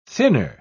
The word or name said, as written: thinner